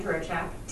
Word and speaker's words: Dzurochak: My name is Diane Durochak, D-Z-U-R-O-C-H-A-K. You can't figure out how to spell it from just saying it.